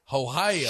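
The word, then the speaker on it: Hohaia: Oh, it's actually better. It's actually pronounced Hoyea.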